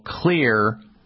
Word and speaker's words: Xlear: I would, one, go to the store, the health food store or some store, and get something called Clear, X-L-E-A-R. That's how you spell it, X-L-E-A-R, Clear Rescue Nasal Spray.